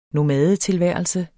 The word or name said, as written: nomadetilværelse